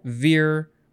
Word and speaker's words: vir: So it is Latin, perhaps from co plus vir, which is spelled V-I-R. It looks like vir, but they would have pronounced it vir.